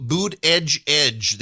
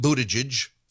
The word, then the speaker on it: Buttigieg: Pete Boot Edge Edge, they told you to pronounce his name that way. Pete Buttigieg.